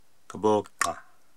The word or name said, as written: Koboa